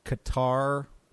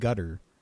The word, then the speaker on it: Qatar: They're being, is it gutter? We pronounce it Qatar or Qatar, the country, Q-A-T-A-R. – Yes. – The Arabic, I believe, pronounces it gutter.